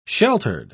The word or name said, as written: sheltered